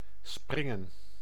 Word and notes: bound: /ˈbaʊnd/; [baʊnd]

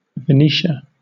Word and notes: Venetia: /vəˈniʃə/; /vɪˈniːʃə/

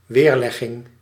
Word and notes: réponse: [ʁe.pɔ̃s]; France

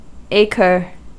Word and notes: acre: American English; [ˈeɪ.kɚ]; /ˈeɪ.kɚ/

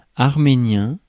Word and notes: arménien: /aʁ.me.njɛ̃/; France

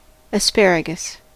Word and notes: asperge: France; [as.pɛʁʒ]

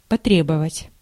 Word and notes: prier: France; [pʁi.je]

